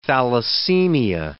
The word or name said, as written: thalassemia